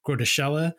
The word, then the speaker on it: Groeteschele: It stars Henry Fonda as the President of the United States, Walter Matthau as the nuclear war strategist, Professor Groeteschele. Although, if you look at it in text in like the novel, it, it, you would almost want to pronounce it grotesque, and I think that's you know by design.